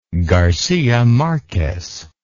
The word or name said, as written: garcia marquez